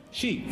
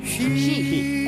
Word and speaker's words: Xi: Bueno, recordemos que el nombre de pila no es Xi. Rajoy nos recuerda cómo se pronuncia. – Xi. – Xi. Es Xi.